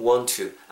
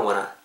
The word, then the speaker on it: want to: to라는 건 to 부정사의 시작을 알리는 그 부, 분이고요 근데 I don't want to라고 발음하시지 마시고 그냥 I don't want to, I don't want to 정도로 발음하시면 됩니다.